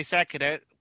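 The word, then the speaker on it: Sakuta: It's like Sakura, but with a T in it instead of an R. – Ryusei Sakuda also known as Kamen Rider Meteor, tries to get along with Gentaro and stumbles into the rabbit hutch after school.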